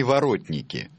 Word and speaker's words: воротники: Если не ошибаюсь, воротники. – Я вам прощу ударение, потому что в том-то весь... Я прощу вам ударение. Алло? – Да. – Все правильно, только они воротники.